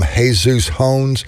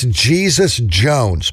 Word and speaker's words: Jesus Jones: We'll start it up with AC/DC, got NXS, some War, some Jesus Jones, and that's not how you say it. It's Jesus Jones, Patty.